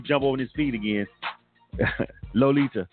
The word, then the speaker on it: Loletadior: L-O-L-E-T-A-D-I-O-R. I-O-R. And she just broke it down and said it's Lolita. L-O-L-E-T-A-D-I-O-R. But it's pronounced Lolita. What in the hell is the I-O-R silent? So the I-O-R is just A.